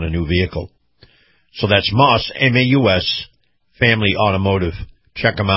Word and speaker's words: Maus: So remember also that our Truckers Highway and Byway Report is brought to you by Moss Family Automotive. Now, Moss is spelled M-A-U-S,